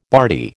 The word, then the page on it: bardy: American English